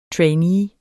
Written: trainee

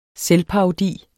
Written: selvparodi

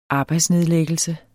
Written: arbejdsnedlæggelse

